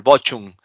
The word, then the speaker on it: Bochum: sí, sí, contra el, bueno, no sé si se pronuncia así, pero el Bochum. – Ah, eso es. – Perdió eh, cuatro eh, 4 el Perfecto, el perfecto alemán.